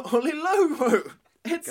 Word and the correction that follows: Oyelowo: But I say less YOLO, more David Oyelowo. It's David Oyelowo.